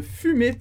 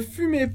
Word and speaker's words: fumez: Ne fumez pas. A gente tem o verbo fumer. Exatamente a mesma pronúncia de fumer e z no final. De fumer e r, que é fumar.